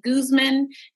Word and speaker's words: Guzman: And then you can also find me on Facebook as Eve Guzman. And my last name is spelled G-U-Z-M-A-N.